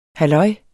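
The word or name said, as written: halløjs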